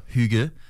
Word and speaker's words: hygge: H-Y-G-G-E. Ça se prononce hygge,